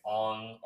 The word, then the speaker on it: Aang: But like, you can't pronounce Aang. It's Ong or General Iro.